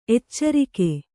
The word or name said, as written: ಎಚ್ಚರಿಕೆ